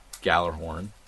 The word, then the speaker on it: Gjallarhorn: It's going to be bringing back some of the old stuff, including Gjallarhorn. I tried to pronunciate the J too much. Gjallarhorn.